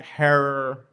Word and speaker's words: Herer: As did Jack Herer. Rhymes with terror.